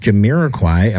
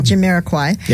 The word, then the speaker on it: Jamiroquai: Well, first of all, I appreciate your pronouncing that, because I've been to Jamiroquai. I mean, anyway, so thank you. – You actually just got it. – Yeah, good job. – Jamiroquai.